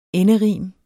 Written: enderim

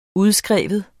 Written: udskrive

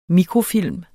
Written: mikrofilm

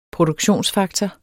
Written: Produktionsfaktor

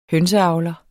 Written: hønseavler

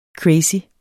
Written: crazy

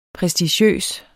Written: prestigiøs

